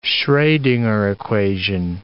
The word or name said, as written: Schrodinger equation